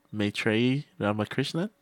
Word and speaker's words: Maitreyi Ramakrishnan: Maitreyi Ramakrishnan.